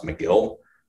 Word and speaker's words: Megill: Uh, I believe his last name is pronounced Megill.